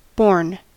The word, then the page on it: borne: American English